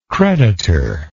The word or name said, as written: creditorship